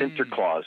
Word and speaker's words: Sinterklaas: Now, the Dutch pronunciation of Saint Nicholas is Sant Niklaus or hmm. Sinterklaas, mm.